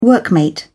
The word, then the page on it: workmate: British English